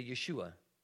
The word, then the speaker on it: Yeshua: They would say Yeshua in their t- at their time.